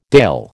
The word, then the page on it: Dàil: American English